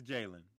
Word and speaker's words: Jalen: It's Jalen. Yes. – Yeah, Milrow. – Jalen. I had it right. Okay, the first time.